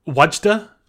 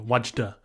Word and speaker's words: Wadjda: Last night I watched Wajda on Netflix. – You watched what? – Wajda. WADJJDA. Wajda.